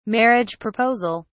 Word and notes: marriage proposal: British English

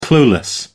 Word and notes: clueless: American English

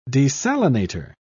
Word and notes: desalinator: British English

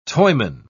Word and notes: toyman: British English

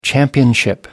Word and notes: championship: American English

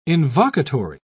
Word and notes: invocatory: British English

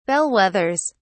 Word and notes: bellwethers: British English

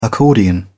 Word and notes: accordion: British English

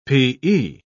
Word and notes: p.e.: British English

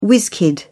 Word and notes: whizz-kid: British English

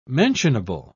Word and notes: mentionable: British English

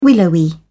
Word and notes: willowy: British English

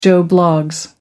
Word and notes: joe bloggs: American English

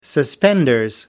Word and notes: suspenders: British English

